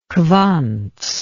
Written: quantz

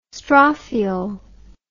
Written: strophiole

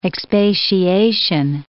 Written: expatiation

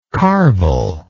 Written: carvel

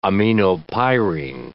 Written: aminopyrine

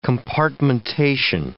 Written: compartmentation

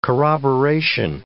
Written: corroboration

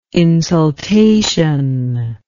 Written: insultation

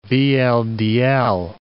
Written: VLDL

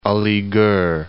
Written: Aligarh

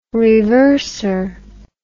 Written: reverser

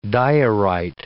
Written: diorite

